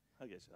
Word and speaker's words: Hargeisa: Ja, troligtvis har fler svenskar de senaste åren besökt Erbil i norra Irak eller Hargeisa. Är det rätt uttal? – Hargeisa, ja det är ganska Hargeisa.